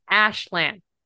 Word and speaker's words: Aslan: And she's like, no, but that's not how English is pronounced. This is an English word. Like, it's an English book. We're just going to go with Aslan. And I was like, no, in my brain, it's Ashlan because that's how I have processed this.